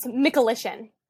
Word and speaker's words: Michalyshen: It's close. Michalyshen.